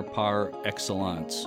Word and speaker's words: par excellence: and engineer par excellence. I think that's how you pronounce that. – That sounded good.